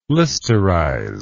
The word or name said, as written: listerize